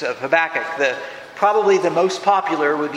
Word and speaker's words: Habakkuk: Habakkuk. The emphasis is on the last syllable, and one of the things I read was it's like saying have a cook.